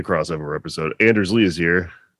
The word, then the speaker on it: hier: How do you say it? H-I-E-R. – Here. – It's just here.